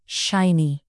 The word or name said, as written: shiny